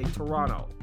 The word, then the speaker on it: Toronto: You say Toronto. The T is silent.